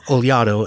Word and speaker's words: Olhado: So I can tell you it's Olhado and Novinha and Marcão, but there's actually in my Nook version there's actually a pronunciation guide for the names.